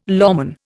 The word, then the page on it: lawman: Indian